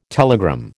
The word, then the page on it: telegram: American English